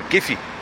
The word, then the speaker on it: Giff: And then I have cousins, and they're called Giffy. – Giffy. – Yeah, you know, they're spelt with a Y, whereas I'm just G-I-F-F, Giff, and then his nickname be Giffy, G-I-F-F-Y.